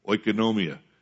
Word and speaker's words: oikonomia: Economy, oikonomia. Sometimes people pronounce the Greek word similar to the word economy.